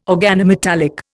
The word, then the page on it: organometallic: Indian